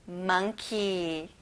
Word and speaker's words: monkey: 여러분, 몽키가 아니라 monkey.